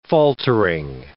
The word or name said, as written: faltering